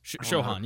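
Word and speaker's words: Sochan: So, how do you say? What's his Shohan? Yeah.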